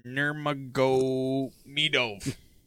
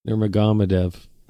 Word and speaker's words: Nurmagomedov: It is Khabib Nurmagomedov. – Nurmagomedov. – Fucking look at that. – Jesus. – How would you pronounce that name? Khabib Nurmagomedov. – Nurmagomedov.